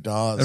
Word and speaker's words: Dawes: Dude, Dawes. – It rhymes with pause.